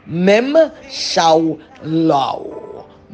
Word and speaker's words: Memshala: M-E-M-S-H-A-L-A-H. But it's pronounced at Mem Shao Lao.